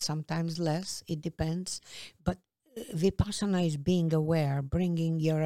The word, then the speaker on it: Vipassana: Yeah, I always say it wrong. – Vipassana.